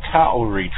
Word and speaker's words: Kauri: I guess I was looking on the internet, and that's called the Kauri tree. It's spelled almost like Maori, but with a K. It's K-A-U-R-I.